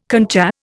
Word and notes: concha: Indian

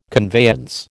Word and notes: conveyance: American English

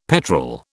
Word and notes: petrel: British English

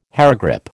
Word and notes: hairgrip: American English

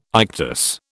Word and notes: ictus: British English

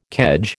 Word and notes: kedge: American English

